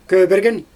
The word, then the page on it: kööbergen: Xyzyl